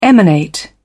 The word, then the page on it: emanate: British English